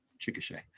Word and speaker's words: Chickasha: Chickasha. I've been saying that wrong my entire life, um, but uh, that I just now realized what you're when you said it. I thought, okay, because I could picture the word, but been saying it wrong.